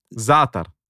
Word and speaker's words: Za'atar: It's the spice, Za'atar.